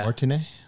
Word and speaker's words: Martinet: Charles Martinet. Yeah. – Martinet.